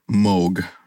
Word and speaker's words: Moog: It's a brand called Moog, or Moog is probably how you pronounce it, M-O-O-G.